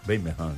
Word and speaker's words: Birmingham: Eles pronunciam Birmingham, né? O inglês.